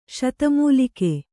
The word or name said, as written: ಶತಮೂಲಿಕೆ